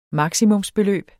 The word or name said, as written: maksimumsbeløb